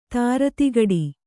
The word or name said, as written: ಧೀರತೆಗಿಡು